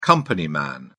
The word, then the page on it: company man: British English